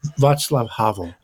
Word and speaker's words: Václav Havel: I remember in I think it was in Visions of Vocation that you talk about Voklak Havik, right? Am I pronouncing the Václav Havel.